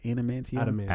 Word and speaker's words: adamantium: The Silver Samurai, I think his armor was made out of adamantium. How do you say it? Adamantium? – Adamantium.